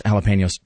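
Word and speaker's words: jalapeño: One, it's pronounced jalapenos.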